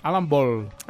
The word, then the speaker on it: Ball: Sí, el que passa és que he, he vist una entrevista que els hi feia IMDB, el director, Alan Bale, es diu? – Alan Ball. – Alan Ball, perdó, que eh, pronunciem malament.